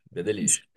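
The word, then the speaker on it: Bédélys: Eu falo Bedelis, mas eu devo estar falando errado. – É verdade. Que delícia. – Bedelis, entendeu? Que nem Flor de Lis, Bedelis.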